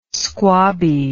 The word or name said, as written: squabby